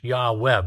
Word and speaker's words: Yahweh: We have just the YHWH because those are the representative consonants of the Hebrew without the vowel points, but it would be pronounced Yahweh, best we can understand.